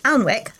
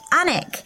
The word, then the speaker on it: Alnwick: So Andy wrote to say several useful things about our Northumberland episode, including that Alnwick, as I would have said it, that's A-L-N-W-I-C-K, is actually pronounced Anik.